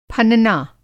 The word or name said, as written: फनना